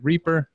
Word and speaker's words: Rieper: Am I pronouncing that last name right? Is it Rieper? – Yes.